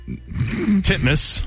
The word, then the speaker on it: Titmus: Pronounce it the way it's spelled. – Ariane Titmus. Titmus.